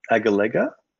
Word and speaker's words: Agalega: Um, India's militarization of an island that belongs to Mauritius, which I'm going to butcher the, the name of, Agalia? – Um, Agalega? – Agalega. No wonder I couldn't say it. – Or Agalega, Agalega. depending if you're in Australia or Britain.